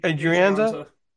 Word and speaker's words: Adrianza: How do you say that name? E. Hire? E. Here? Adrianza? – Adrianza.